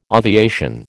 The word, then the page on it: aviation: American English